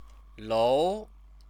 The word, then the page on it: lou: chinese